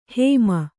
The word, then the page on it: ಹೈಮ: kannada